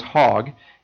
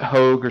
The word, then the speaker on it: Hogg: His last name was Hogg, H-O-G-G, and probably growing up, people would say, oh, you should pronounce it Hogue or something like that.